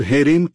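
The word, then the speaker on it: हेरेम (חרם): क्रिया रूप हराम है। अब हेरेम, इसका उच्चारण हेरेम है, यह कठिन है।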